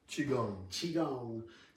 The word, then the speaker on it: Qigong: Qigong session that you guys can check out.